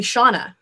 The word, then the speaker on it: Shana: Well, she named me after Shana Alexander, S-H-A-N-A, right, and then called me Shauna. Right? And then... – Thanks, Mom. – Yeah, and so people call me Shauna.